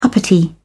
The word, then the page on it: uppity: British English